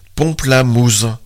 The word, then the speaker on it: Pomplamoose: Single Ladies de Beyoncé, interprété par le duo américain Pomplamoose, soit Pamplemousse prononcé approximativement par des Américains.